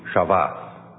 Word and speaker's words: Shabbat: Sabbath is a straight Hebrew word, not pronounced quite like that. The S is a sh, so it starts with sh, and the B's are pronounced as V's in some pronunciation, so it would be Shabbat.